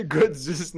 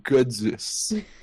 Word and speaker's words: Godus: D'abord, il y a une barre de recherche, tapez Godus. ça s'écrit comme God US.